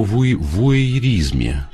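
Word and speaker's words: вуайеризм: Точнее говоря, о подглядывании, о вуэйризме. Вот как это правильно произносится.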